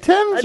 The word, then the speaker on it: Thames: The saviour, in part, was due to a few square miles across the Thames, South London. – You said Thames, right? – I said it right.